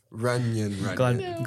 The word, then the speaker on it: Runyan: Runyon. Glad you pronounced that correctly. – It's like Funyuns, but Runyon.